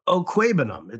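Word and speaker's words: Okwuegbunam: It's Okwebenum. It's not a difficult one.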